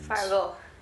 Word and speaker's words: Fargo: Fargo. You have to say it like that.